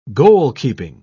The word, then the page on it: goalkeeping: British English